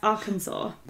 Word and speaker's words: Arkansas: Did you know that's pronounced Arkansas? – I did not.